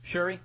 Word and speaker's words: Shuri: Shiri, is that how you say her name? – Shuri, yeah. – Shuri, yeah.